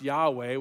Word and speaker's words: Yahweh: which best we know is pronounced Yahweh, which is sounds like the Hebrew verb for he is.